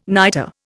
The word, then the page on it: nitre: Indian